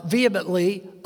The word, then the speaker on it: vehemently: Yeah, my wife says H is silent. I don't know English. They threw an H in there. It ought to be said. No. Vehemently accusing him.